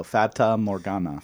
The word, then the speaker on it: Fata Morgana: That's not how you pronounce it? It is not biology. – Oh, okay. Oh, but that's how you pronounce it, though. Fata Morgana.